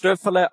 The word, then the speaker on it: Stöferle: And you pronounced Stöferle almost perfect.